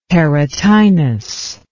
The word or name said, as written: perotinus